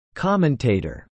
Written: commentator

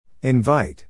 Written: invite

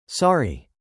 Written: sorry

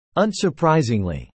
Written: unsurprisingly

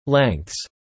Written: lengths

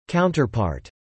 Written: counterpart

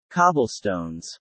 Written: cobblestones

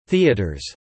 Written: theatres

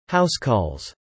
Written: housecalls

